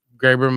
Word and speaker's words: Graber: It's spelled wrong. That's why I didn't know. It's literally spelled Graber. That's why I was like, that doesn't look right. – It is Graber.